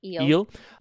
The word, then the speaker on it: Ils: But there's a film coming out of France called, the French title is Ils. If I'm saying that correctly, please correct me. It's ILS. So is that just Ils? – Eel.